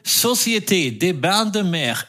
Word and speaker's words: Société des Bains de Mer: Ja, besser lief's da nach längerer Durststrecke zuletzt bei der, Achtung, Société des Bains de Mer et du Cercle des Etrangers à Monaco. – Das hast du wirklich wunderbar äh, fehlerfrei ausgesprochen.